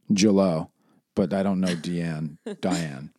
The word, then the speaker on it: Diane: Deanne Arbus. – Deanne. – Deanne. – Why is it written as Diane? – Well, it's spelled as Diane, but she pronounced it Deanne. – So let's say Diane then.